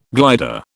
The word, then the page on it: glider: British English